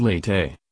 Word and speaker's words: Late: Before going any further from this temple, pilgrims pay their obeisance to Late, pronounced Late, meaning lying down, Hanuman.